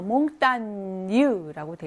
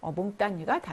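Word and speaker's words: montagne: 5번에는 산이라는 단어 몽땅뉴라고 되어 있죠. 쥐하고 N이 만나서 뉴 발음으로 나죠. 그래서 정답은 5번이죠. 이건 느 발음이 아니죠. 그래서 몽땅뉴가 답이 되겠습니다.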